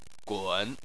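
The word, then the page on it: gun3: tone 3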